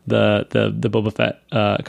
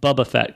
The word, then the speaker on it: Boba Fett: Like, you know, I'll take that over the Boba Fett cartoon any day, or the Bubba Fett cartoon, as Bruce Valanche pronounces Boba Fett.